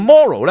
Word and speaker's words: Moreau: 咁呢个 Morrow 咧好有趣嘅，佢啊 M O R E A U 咁啦吓，读 Morrow 啦。